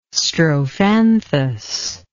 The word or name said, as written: strophanthus